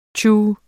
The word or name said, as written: tju